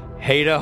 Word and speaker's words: Haida: Produced by Stephen Hine, written by Stephen Hine and Haida Holscher, as in, it rhymes with hate, like I hate it when you guys mispronounce my name for two seasons.